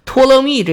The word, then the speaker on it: 托勒密: 这个 Neo 就是新的，托勒密呢就是战士的意思。所以当时讲托勒密的时候，我没讲，就是想把这事儿啊留在这儿一起说。托勒密这个希腊语译音还是很准的，不过呢，它前面呢有一个 p 是不发音的，而 neo 托勒密呢，其实那 p 也不发音。